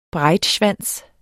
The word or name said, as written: breitschwanz